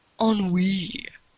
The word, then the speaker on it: ennui: Ennui. If you're going to sound like a douche and speak French, at least pronounce it Frenchly.